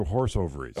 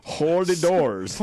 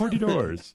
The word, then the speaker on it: hors d'oeuvres: Do you pronounce it hors d'oeuvres or horse ovaries? – Horse ovaries. Hors d'oeuvres. – Hors d'oeuvres.